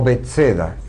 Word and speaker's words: Betsaida: que se llamaba Betsaida o Betseda, como se la pronuncia.